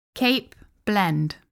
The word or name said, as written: cape blend